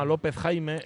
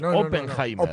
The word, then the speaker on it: Oppenheimer: Hay quien lo llama López Jaime. El apellido ah, es Oppenheimer. Oppenheimer. Oppenheimer.